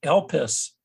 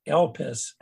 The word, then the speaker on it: Elpis: And it comes from a Greek word that is pronounced Elpis. – Mm. – Elpis. – Elpis Presley? – Not Elvis.